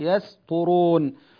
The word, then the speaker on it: يسطرون: يسطرون يعني ننبه على هذه، الطاء حرف مفخم والسين حرف مرقق، فالسين بالنسبة للطاء ضعيفة لأن الطاء أقوى الحروف، فالذي يعني يؤثر في الآخر هو الطاء، فبدل ما ينطق القارئ السين سينا ينطقها صادا. لماذا؟ لأنها تناسب الطاء، فيقول يسطر، ولذلك القارئ يتحفظ، يقول يس ينطق السين هكذا يس ثم ينطق الطاء يسطرون.